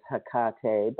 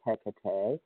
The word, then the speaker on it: Hecate: One of my favorite grandmothers is, a lot of people will pronounce her as Hecate, but I say Hecate.